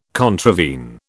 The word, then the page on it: contravene: British English